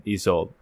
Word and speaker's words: Isolde: I love the horned serpent because it was Isolde. Am I pronouncing that right? Isolde? – I believe it's Isolde.